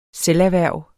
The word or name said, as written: selverhverv